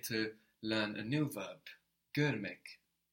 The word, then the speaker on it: Görmek: And here actually we learn another vowel in Turkish. Görmek is spelled G-O with two dots above the O. R-M-E-K. This is the last vowel we have to learn in Turkish. The last one. The vowel here is exactly like the vowel we have in English.